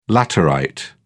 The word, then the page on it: laterite: British English